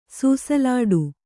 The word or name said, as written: ಸುಸಿಲಾಡು